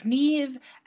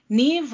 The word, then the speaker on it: Niamh: This is a story of Niamh and Oisín. Not pronounced, not written to be pronounced like that at all. I had to Google that.